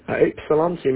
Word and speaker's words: Ypsilanti: Yes, the YP is kind of silent. Everybody pronounces it IP, IP, like an IP address, Ypsilanti. It's got a Y and a P, but we call it Ypsilanti.